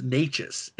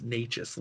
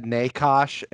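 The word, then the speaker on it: Nečas: And I finally found out how to pronounce uh, uh, the number 88 on Carolina, it's it's it's Natchez. It's spelled N-E-C-A-S, but it's pronounced Natchez.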